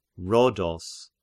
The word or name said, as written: Rhodos